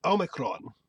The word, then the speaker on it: Omicron: And in Greek, it would be like Omicron with like a rolling R.